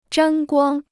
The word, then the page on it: 沾光: zhān guāng